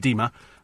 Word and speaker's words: Dima: His name's Dimmer. It's pronounced Dima, but it's D-I-M-A.